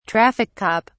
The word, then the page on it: traffic cop: British English